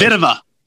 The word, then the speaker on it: Kvitova: I think this was the number 11 ranked tennis player, Petra Kvitova. I don't know if that's her pronunciation. – Kvitova. – Kvitova. There you go.